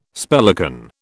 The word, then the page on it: spellican: British English